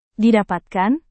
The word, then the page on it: didapatkan: indonesian